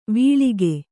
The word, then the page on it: ವಳಕು: vaḷaku